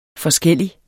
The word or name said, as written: forskellig